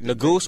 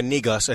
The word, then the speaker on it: Negus: Negus, or Negus, as some pronounce it, means king in the Ethiopian language of Amharic.